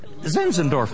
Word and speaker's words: Zinzendorf: Zinzendorf.